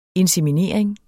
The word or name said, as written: INSEMINERINGER